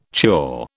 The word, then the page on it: chore: British English